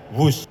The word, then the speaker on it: Whoosh: Dalam acara peresmian pada hari Senin 2 Oktober, Presiden Jokowi mengatakan kereta cepat pertama di Asia Tenggara ini dinamakan WUZ. Menurutnya nama tersebut terinspirasi dari suara yang terdengar melesat dari kereta berkecepatan tinggi dan merupakan cerminan dari waktu operasi optimal sistem hebat. – Sekali lagi, W-H-O-O-S-H, tapi membacanya WUZ.